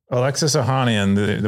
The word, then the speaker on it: Ohanian: Ohanian? – Alexis Ohanian, the one of the original co-founders.